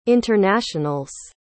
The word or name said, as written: internationals